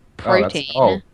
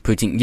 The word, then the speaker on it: poutine: Is it called poutine or whatever? – Protein. – Oh, yeah, that's Canadian. – What is it called? How do you say it? – Poutine.